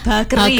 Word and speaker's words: bakery: Is it correct if I mention or I pronounce it bakery? Ini in Japanese we call it bakery. – Bakery. – I think you are almost close to how it is pronounced, but it is bakery.